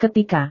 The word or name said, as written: ketika